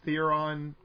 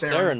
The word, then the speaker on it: Theron: First one was the Charlize Theron movie, Atomic Blonde. It's Theron, my friend. Get her name right.